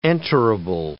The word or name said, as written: enterable